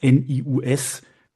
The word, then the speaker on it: NIUS: Aber ja, dieses, äh, dieses Format hat eine ganze Weile gedauert, bis ich geschnallt habe, dass dieses NIUS wie das englische News ausgesprochen werden soll.